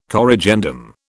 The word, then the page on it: corrigendum: British English